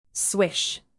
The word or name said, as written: swish